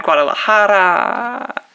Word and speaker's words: Guadalajara: We had Guadalajara. We had thank you for pronouncing that correctly.